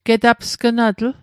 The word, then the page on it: getapsgnatl: Mi'gmaq